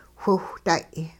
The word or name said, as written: whiwhday'